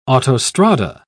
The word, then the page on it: autostrada: British English